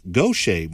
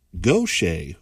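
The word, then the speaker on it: Gauthier: So we've been saying this name wrong. It's pronounced Gaucher, Mary Gaucher, who sang Soldier of Fortune this morning.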